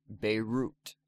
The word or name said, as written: Beirut